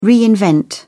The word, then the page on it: reinvent: British English